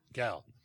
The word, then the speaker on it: Gal: Is that her proper pronunciation? – Yeah. – Gal.